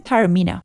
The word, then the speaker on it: Taormino: Without further ado, here's my conversation with Tristan Taormino.